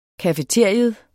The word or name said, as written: cafeteria